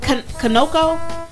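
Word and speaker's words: Conoco: Two people were arrested after an attempted robbery of a, is that Canoco? I think is how you say it. – Mm-hmm.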